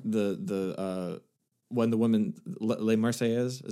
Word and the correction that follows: La Marseillaise: is that, I'm not saying it right. – La Marseillaise. Yeah.